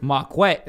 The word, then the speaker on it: Marquette: Wait, it's pronounced Marquette? – Marquette. – Is that how it's pronounced? – Yeah, yeah. Marquette King.